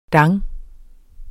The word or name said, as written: dang